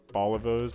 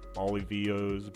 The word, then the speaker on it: Bolivos: They were called the Bolivos, but people kept like mispronouncing it or not knowing how to pronounce it. They called them Bolivians, Bolivios, Bolivos, shit like that.